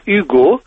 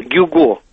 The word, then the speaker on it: Гюго: Вот именно так, «Гюго», оно произносится по-французски, а не «Гюго».